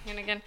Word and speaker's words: Hannigan: Allison Hannigan. – That's right. – Did I say that right? – Allison Hannigan. – Hannigan.